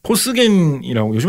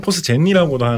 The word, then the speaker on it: 포스겐: 실제로 1차 대전 때 사상자가 가장 많이 발생한 화학 무기는 포스겐이라고 요즘에는 포스젠이라고도 하는 것 같은데 발음이 좀 바뀌면 포스겐이라고 기록이 되어 있는데 포스겐도 현대 공업화에서 굉장히 많이 유용하게 사용돼가지고 엄청나게 많이 만들어낸 물질입니다.